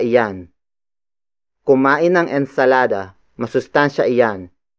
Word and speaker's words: iyan: The next pronoun is iyan. Iyan. Iyan. Iyan is spelled as I-Y-A-N.